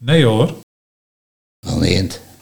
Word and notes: Belneemt: Nieuw lekkerlands

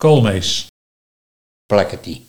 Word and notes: Plakkertie: Nieuw lekkerlands

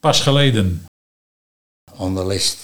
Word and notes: Onderlest: Nieuw lekkerlands

